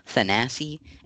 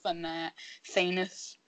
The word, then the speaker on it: Thanos: Thanasi? Is that how you say it? Or is it pronounced Thanos? – Thanos.